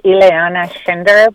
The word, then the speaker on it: Ileana Schinder: Ileana, am I pronouncing your name correctly? – Yes, you are, Tom. Yes, my name is Ileana Schinder, but yeah, you're pronouncing it very, very well.